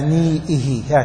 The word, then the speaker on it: نيئه: ايه، ها، مشدد، هذه مخففة مما يشددها الناس وهي مخففة. قل ولا نيئه، هكذا تنطق، ولا نيئه بمطبوخه، مثل اللثة، لا تقول اللثة، قل اللثة بالتخفيف.